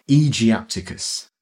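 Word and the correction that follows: aegyptiacus: Well, you're the classicist, Egyptiacus.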